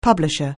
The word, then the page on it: publisher: anglais